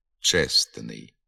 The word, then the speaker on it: чесний: Так, наприклад, при творенні прикметника від іменника «честь» виник збіг трьох приголосних «СТН» – «чесний». І середній приголосний «т» зник у вимові. Він не фіксується і на письмі. Вимовляємо і пишемо «чесний».